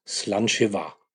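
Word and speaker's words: Sláinte mhath: Und Gälisch gibt es natürlich das Wort Prost auch und das heißt Slanchevar. spricht sich nicht so schwer, aber geschrieben wird es ganz anders. Könnte man nie ermitteln, wenn man nur den Text liest. Aber es spricht sich aus Slanjeva.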